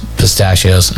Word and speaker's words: pistachios: I was going to say spitachios. I said pistachio. Did I say it right? No, I screwed up again.